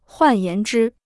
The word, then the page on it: 换言之: huàn yán zhī